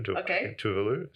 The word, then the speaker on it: Tuvalu: Tuvalu, is how you say it.